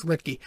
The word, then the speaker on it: Luetge: Finally said it right, by the way. I finally got the name right. I, I heard it today, and I was okay. It's Luetge.